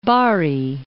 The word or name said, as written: Bari